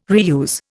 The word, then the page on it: reuse: Indian